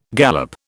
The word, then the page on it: galop: British English